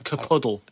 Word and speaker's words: Kapuddle: It's called Capuddle. – Speak it. Spell it, I should say. – K A P U D D L E. – Capuddle. – Spelled as it sounds. – Yeah, with a K.